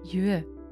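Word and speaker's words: yue (乐): In that context, it is pronounced yue. The other meaning is joy, where the pronunciation changes to le.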